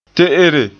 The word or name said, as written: te'ere